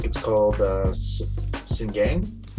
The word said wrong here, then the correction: Sinigang: Sinigang. – Sinigang. Ah, yeah.